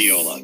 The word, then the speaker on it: Viola: Viola, good, good Italian boy from Cleveland, Ohio.